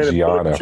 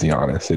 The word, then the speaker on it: Giannis: I guarantee you it's Giannis. It is not Giannis.